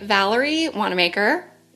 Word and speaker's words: Valerie Wanamaker: I have today Valerie Wanamaker.